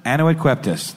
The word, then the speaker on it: Annuit coeptis: Annuit coeptis.